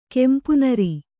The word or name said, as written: ಕಂಪುನೀರ್